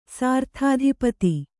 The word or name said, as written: ಸರಿದಧಿಪತಿ